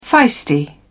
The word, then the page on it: feisty: British English